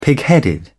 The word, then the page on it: pigheaded: British English